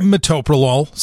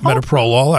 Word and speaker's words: metoprolol: If I'm late on my heart meds, like Losartan and, what is it, metoprolol? How do you say that word? – Metoprolol. Some people call it metoprolol.